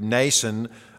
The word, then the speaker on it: Mnason: I think the M is silent there, but Mnason of Cyprus, a disciple of long standing with whom we were to lodge.